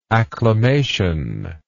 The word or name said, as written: acclamatory